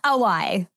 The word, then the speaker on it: Auwai: Did I say that right now? – Auwai.